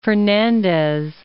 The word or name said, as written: Fernandez